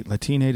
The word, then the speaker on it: Latine: This is the scene in the re-recording where they had the corn on the grill and some Latine members of Disney. Latine, did I say it right? – Yeah.